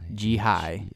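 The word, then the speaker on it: Jihei: It's J-I-H-E-I. Jihai. Jihai. – Jihai. – I think it's Jihai. That's how I pronounce it in my head.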